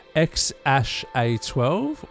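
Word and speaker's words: X Æ A-12: Boucher and Musk have stated that they named the child as XEA-12, pronounced X-A-12 or X-A-I.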